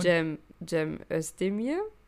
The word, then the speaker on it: Cem Özdemir: Da kam doch Cem Özdemir. – Ja. – Oh, das war jetzt sehr deutsch ausgesprochen. – Ja, und? Cem Özdemir. Entschuldigung.